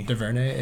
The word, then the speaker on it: DuVernay: I can't pronounce her name, Ava DuVernay. – DuVernay, yeah.